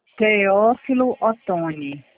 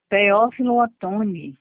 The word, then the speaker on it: Teófilo Otoni: Teófilo Otoni. – Cleófito, Cleófito. Nossa, gente, não consigo pronunciar. – Teófilo Otoni. – Preófito, não.